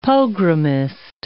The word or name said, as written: pogromist